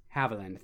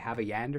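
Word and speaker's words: Havilland: Haviland, thank you. I don't know why. I always want to say Havayand or something.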